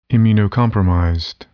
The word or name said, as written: immunocompromised